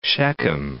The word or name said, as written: Shechem